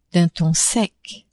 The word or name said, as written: d'un ton sec